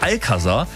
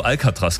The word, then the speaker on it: Alcazar: Und legendärer 2000er-Disco-Klassiker von, ich muss es richtig aussprechen, die Band heißt Alcazar. Mhm. Ich habe schon so oft im Radio Alcatraz gesagt, diese Gefängnisinsel.